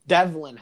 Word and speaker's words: Devlin: Delvin Hodges. – Devlin Hodges. Devlin Hodges, not Delvin.